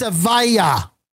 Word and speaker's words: Aviva: So then she goes some more names and she says, Aviva Lopez. Excuse me. And the woman says, yes, it's a vaya. The accent's on the second syllable.